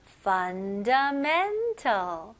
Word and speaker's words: fundamental: 네, 잘 들어보시면 이렇게 긴 단어들은 강세가 주로 두 군데에 들어간 경우가 많아요. 그래서 men 여기에가 제일 강한 강세가 들어가고 앞부분에 fun도 들어갑니다. 그래서 fundamental. Fundamental. 네, 이런 리듬으로 한번 읽어보시면 훨씬 더 자연스럽고 원어민스러운 발음으로 할 수 있습니다.